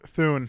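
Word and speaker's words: Thule: How do you pronounce the bicycle rack thing? – Thune. – Thune? Because I was having an issue with that on Tradio yesterday. I was like, Thune, Thune. – I was listening. – Thune. All right. Rhymes with Zune.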